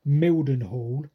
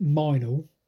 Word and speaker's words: Mildenhall: now you look at a map and you pronounce it Mildenhall, but apparently all the Right. – yokels pronounce it Minal.